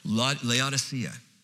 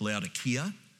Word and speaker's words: Laodicea: Laodicea. Actually, it's if you're speaking the language in the Greek, it's Laodikia. The C is a hard C, so it's Laodikia.